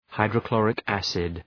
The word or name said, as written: hydrochloric acid